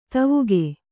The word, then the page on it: ಧಾವಕ: dhāvaka